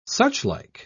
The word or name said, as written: suchlike